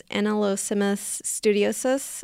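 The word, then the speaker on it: Anelosimus studiosus: So this is the, I think it's pronounced Anolosimus studiosus. – That sounds right to me, yeah.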